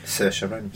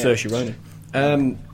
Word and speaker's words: Saoirse Ronan: Saoirse Ronan. Yeah. – Saoirse Ronan.